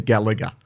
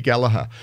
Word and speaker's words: Gallagher: They say Gallagher. And it's like, yes, there is that second G in there, but it is not pronounced. And as she herself demonstrates, hi, I'm Katie Gallagher, and you're listening to 2XX.